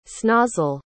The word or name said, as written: snozzle